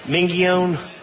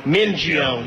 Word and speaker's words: Mingione: Mingione? I know that's not right. That's Mingione.